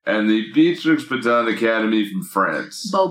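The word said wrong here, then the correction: Beauxbatons: Bobaton. – That was my fault. You're right. – Bobaxon? – Yeah. I remember, they're French, so you don't pronounce it.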